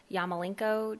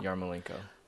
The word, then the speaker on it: Yarmolenko: And then soccer, you look mighty fantastic in your new Yarmolenko jersey. Did I say it right? – Yeah. Oh, close. Yeah. I think you said it right. – Yarmolenko? – Yarmolenko.